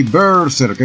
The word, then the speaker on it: Berserk: Se pronuncia así Berserk, es una palabra vikinga.